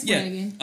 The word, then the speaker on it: Flanagan: Galen Rupp is I mean, Flanagan. Is that my saying her name right? – Yes, Flanagan.